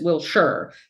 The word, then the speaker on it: Wilshire: So when the guy, when he was done, he was like, yeah, I encountered this lady heading south on Wilshire. Now that was a tip off. The guy said Wilshire, not Wilshire. Everyone who lives here knows it's Wilshire.